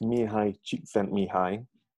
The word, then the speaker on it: Mihaly Csikszentmihalyi: It's Mihaly Csikszentmihalyi.